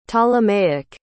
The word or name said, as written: ptolemaic